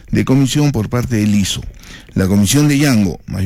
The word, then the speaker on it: Lizzo: L-I-Z-Z-O, así se escribe, así se pronuncia. Lizo Yango.